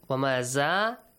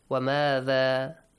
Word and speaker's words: وَمَاذَا: Wamaza is wrong. Wamada.